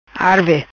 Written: Arve

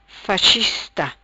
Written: Faichista

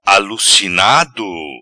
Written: Alucinado

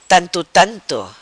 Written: Tanto tanto

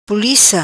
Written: Pulica

